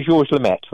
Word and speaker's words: Lemaître: Now, you had mentioned, I would see if I can say this correctly, Monsignor, is it George Lemaître? Did I say that right? – Yeah, Monsignor Georges Lemaître.